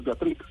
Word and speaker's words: Beatrix: Bueno, Freddy, es primero, ¿es Beatrix o Beatriz? – Pues en español es Beatriz. – Ah, bueno. Y el nombre en holandés es Beatrix. Ah. pero, pero obviamente en español pronunciamos Beatriz el nombre.